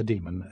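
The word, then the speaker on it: dæmon: Yeah, I pronounce it demon, but it is spelled D-A-E with a ligature, M-O-N.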